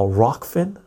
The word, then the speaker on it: Rokfin: and there's Ruffkin and there's so much other stuff. I get notifications from Odysee, I get notifications from Ruffkin, I get oh, or not Rofkin, uh, Rokfin. I pronounce it.